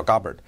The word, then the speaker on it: Gabbard: 其比较重要的人，首先就是国防部长 Pete Hegseth，然后呢 Marco Rubio 在这个里面，副总统在这个里面，国家安全情报局的负责人 Tulsi Gabbard 在这个里面，或者叫 Gabbard，他这名字我听了两个不同的发音，普遍现在叫 Gabbard。